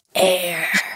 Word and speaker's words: AIRE: Starts with this thing with a very mysterious name, AIR. – Like air that we breathe, AIR? – It's spelled A-I-R-E, and it stands for autoimmune regulator.